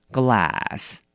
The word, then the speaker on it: glass: 那么这个 a 一定要特别提醒你，发音的时候请你要记得了，嘴巴压扁并且用稍微用力的往左右两边拉开，念成 glass glass， OK，杯子。